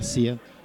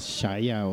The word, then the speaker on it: Sia: con un crush con esta mujer, con Sia, que ya me dijeron que se pronuncia Sia. – Yo pensé que era más mamón ir era Shaya o Saya.